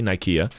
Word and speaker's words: Nicaea: It would have been pronounced with a hard K. It's the city of victory, Nike, like victory, Nicaea.